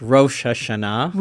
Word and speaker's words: Rosh Hashanah: Now, I'm probably not saying it right. I'm saying it like a wasp, Rosh Hashanah. You say it for us. – Yes, Rosh Hashanah.